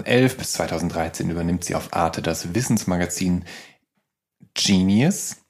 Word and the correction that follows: Xenius: Ist das so korrekt ausgesprochen? – Xenius. Xenius, mhm.